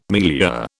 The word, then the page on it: milieu: British English